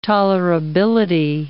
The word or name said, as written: tolerability